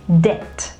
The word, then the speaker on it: debt: There is one alphabet that is silent. You don't sound it, okay? And it is the B sound. It is not debt, but it is debt, okay?